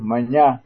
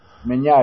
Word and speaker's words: Maignan: Maignan se escribe, ¿no? En lo normal, pero es Meñá. Meñá, hay uno que se va a imaginar que sea Meñá. Y se escribe Maignan, o sea...